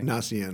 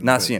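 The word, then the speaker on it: Gnacin: Nacian. – Nacian.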